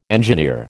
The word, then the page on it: engineer: American English